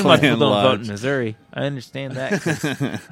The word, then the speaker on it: Missouri: We can't do that in Missouri. You have to go stand in line. – You said it wrong. Missouri.